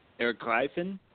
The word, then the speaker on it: Ergreifen: Ergreifen.